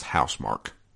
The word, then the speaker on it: Housemarque: Apparently it's pronounced Housemarque.